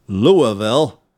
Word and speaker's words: Louisville: It's Louisville.